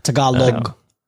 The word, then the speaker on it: Tagalog: You say it like Tagalog. There's a Tagalog. G in the end. Tagalog. – Like a Tagalog. – Like there a you log? Go. Like a log, yeah.